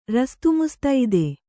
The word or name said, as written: ರಸ್ತುಮುಸ್ತೈದೆ